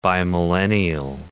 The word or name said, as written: bimillennial